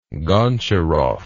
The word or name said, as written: goncharov